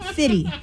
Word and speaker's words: 50 Cent: We pronounce it Fitty. – Well, that's how you probably pronounce it in Stanwood, but in Puyallup, we say 50.